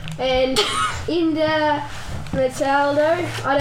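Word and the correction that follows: Éder Militão: Edam Militao, ladies and gentlemen.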